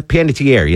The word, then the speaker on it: Panettiere: Panettiere, am I pronouncing that right? – Panettiere, yeah, passed away suddenly at the age of 36, and that woke me up from a deep sleep.